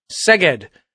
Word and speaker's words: Szeged: Szeged.